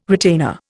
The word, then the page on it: retainer: Indian